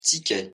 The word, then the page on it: ticket: French